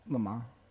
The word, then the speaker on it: Le Mans: Also, you don't pronounce the last S on Le Mans. – The what? – The last S on Le Mans. – Le Mans. – Or the N. Apparently, Le Mans.